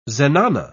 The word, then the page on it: zenana: British English